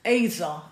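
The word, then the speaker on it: Ezer (עזר): So the word helper is the Hebrew word Ezer, A-A-E-E-Z-E-R, E-Z-E-R, but it's pronounced Ezer, A-Y-Z-E-R, Ezer.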